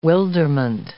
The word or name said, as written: wilderment